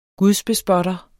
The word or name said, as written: gudsbespotter